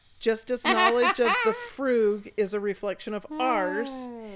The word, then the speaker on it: Frug: But she goes on to say, which he pronounced as if it rhymed with rug, which is just how I pronounced it, rather than moog. – Like the frug? – M-O-O-G. Moog, the frug.